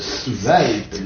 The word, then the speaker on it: svelte: Bon, prononciation correcte, svelte, n'est-ce pas? – Ah, svelte, oui, disent.